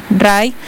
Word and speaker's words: Rhye: Y otra banda que esta mañana anunció, ahorita que estamos hablando de música, de conciertos, por primera vez en Quito también RIE o RAI, ¿cómo se escribe? R-H-Y-E, RAI.